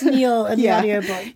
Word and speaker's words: Niall: In the audiobooks, Ronan's dad's name is pronounced Neil, not Niall.